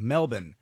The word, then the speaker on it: Melbourne: Melbourne, Australia, I guess it's Melbourne. – Melbourne. – That's the way you say it, more like Melbourne.